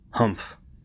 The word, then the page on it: humph: American English